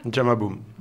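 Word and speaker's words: Jamma Boom: Jama Boom. – Jama Boom. Ça s'écrit J-A. – M-M-A. – M-M-A. – Et puis Boom, B-O-O-M.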